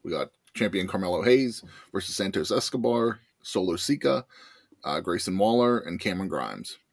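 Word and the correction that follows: Sikoa: It's Sokoa, but playing along for shits and giggles.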